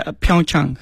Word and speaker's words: Pyeongchang: I still don't know the pronunciation. – It's Pyeongchang.